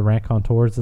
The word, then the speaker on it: Raconteurs: Is it also a band called the Racketeers? – Put in my ass. – It's a Raconteurs. – The Raconteurs is how you say it.